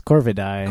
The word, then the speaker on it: Corvidae: And I keep mispronouncing it. It's Corvidae. – Corvidae. – Actually. – It's that weird AE pushed together thing.